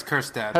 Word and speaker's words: Kjerstad: Heston Kjerstad. – Do you pronounce the J? I thought it was just Kjerstad. – You're right, it's Kjerstad.